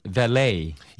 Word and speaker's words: valet: 발음은 여러 가지예요. 예, 근데 제가 쓰는 발음은 valet. – Yes, I believe you know more about this than I do. It's probably French language. Uh-huh. V-A-L-E-T, valet.